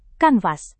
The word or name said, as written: kanvas